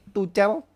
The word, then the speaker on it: Tuchel: Tuchel, Tuchel.